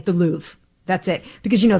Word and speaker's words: Louvre: I definitely don't even come close to speaking the language, but I was corrected that it's Louvre, Louvre, when you want to sound educated about the Louvre.